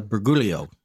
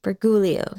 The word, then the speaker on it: Briguglio: I would call it uh, Briguglio. – Briguglio, yes.